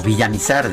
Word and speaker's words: Villamizar: Ay, pobre de Jorge Villamizar, eh, o Villamizar, de hecho, para ser correcto, esa es la, forma correcta de pronunciarlo, Jorge Villamizar,